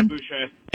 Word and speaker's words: Boucher: He writes, unless I heard wrong, the cheap shot on Thrill was applauded by Brian, is it Boucher? How do you pronounce it? On ESPN? – Oh, yeah, Boucher.